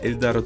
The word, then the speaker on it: Эльдар: И вот, в 2013 году Эльдар Джангиров, а для Америки просто Эльдар, фамилия Эльдара трудно для его американской публики, поэтому на обложках его дисков и афишах выступлений стоит просто Эльдар. Причем, прошу заметить, ударение ставится на первый слог.